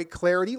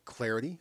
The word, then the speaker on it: Clardy: And you can give me the follow on Twitter at Troy Clarity. Last name is spelled C-L-A-R-D-Y at Troy Clarity.